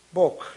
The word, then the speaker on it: Bog: Bog, it's kind of rhymes with coke.